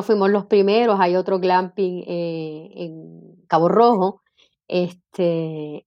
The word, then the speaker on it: glamping: Explícanos qué es eso de glamping.